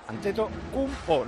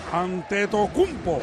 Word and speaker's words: Antetokounmpo: Ya te he oído 2 veces compu, es cumpo. Antetokounmpo, no compu. – Antetokounmpo.